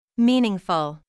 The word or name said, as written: meaningful